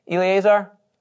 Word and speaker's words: Eleazar: Eleazar? – Sounds great.